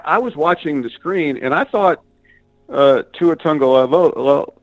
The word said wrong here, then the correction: Tagovailoa: I mispronounced it already, Tagovailoa, had his helmet on and was about to come in the game.